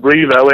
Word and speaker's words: Revo: We pronounce it Revo, and it is short for revolution, so maybe it's our pronunciation again.